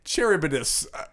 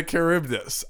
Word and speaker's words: Charybdis: Charybdis, but you can say it like that. – I think it is a Charybdis. I think that's how you say it.